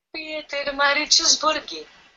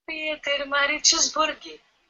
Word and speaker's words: Pietermaritzburg: Tá, é em africaner. Africane, né? Ela vai repetir aqui para nós. – Por favor. – Vai. – Peter Maritzburg. – Então tá, fica aí a pronúncia correta da cidade de partida.